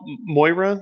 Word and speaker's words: Moira: The new character, am I pronouncing this right, Zell? Moira? Is that how they're saying it? – Yeah.